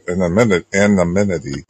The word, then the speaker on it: anonymity: as a reporter to keep his anonymity. How do you say that? – Anonymity. – Yeah, there you go.